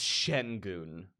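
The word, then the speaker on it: Şengün: And also, we're saying his name wrong. It's Sengun. My mistake.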